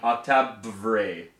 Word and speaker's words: Octabvre: It's spelled O-C-T-A-B-V-R-E. Octave-Vre. Octave pedal.